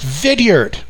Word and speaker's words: Vidyard: And it's called Vidyard, pronounced Vidyard. I think it is anyway. V-I-D-Y-A-R-D, free app.